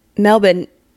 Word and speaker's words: Melbourne: And so I went to Melbourne. – So is it Melbourne? Do you say the R? – No, you're supposed to say Melbourne. – Melbourne.